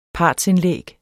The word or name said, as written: part